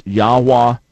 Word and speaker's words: Yahweh: He said, I personally believe the correct pronunciation is Yahweh.